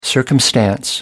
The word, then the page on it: circumstance: American English